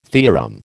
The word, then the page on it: theorem: American English